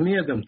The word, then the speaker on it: Медем: Я хочу сказать, что Владимир Медем, так принято вообще называть, на первом слоге ударение ставить на его фамилию.